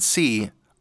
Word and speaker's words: Si: Or up here, Si. Now you might notice that it sounds just like the letter name C, but in solfege it's spelled SI, kind of like the Spanish word for yes.